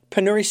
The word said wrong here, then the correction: penury: City? – I think it's penury, but... – Penury. All right. – Yeah. So we'll, penury. It means extreme poverty.